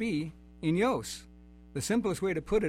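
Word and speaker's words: enios (αἰώνιος): He's a noted Greek scholar, and he put it this way, that the word for eternal is, how is that pronounced? Is it enios? – I've always pronounced it enios. – Enios, okay. – Or right. – eon, the shorter version is eon.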